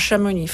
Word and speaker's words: Chamonix: Et ce campus munitus s'est transformé en français en champ muni, un champ muni, et plus tard, dans le langage, en Chamonix. Forcément, le X à la fin ne se prononce pas.